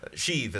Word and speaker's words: Sheev: Sheev is how his name is pronounced.